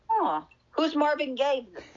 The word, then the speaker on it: Gaye: Oh, so it's not like Gabe. I gave you a dollar. – No, it's Gaye, like the sexual orientation.